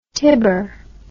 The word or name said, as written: tibor